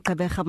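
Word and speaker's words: Gqeberha: Now you say Kabeka and you have to correct certain people, have to help them pronounce the name correctly. Mm. So that's where I'm from, Kabeka.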